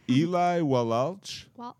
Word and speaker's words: Eli Wallach: Eli Wallach. – Wallach? – And Lee Van Cleef.